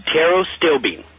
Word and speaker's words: pterostilbene: How do you pronounce it? Pterostilbene? – Pterostilbene. – Pterostilbene. – You got it. – Like Regis Philbin.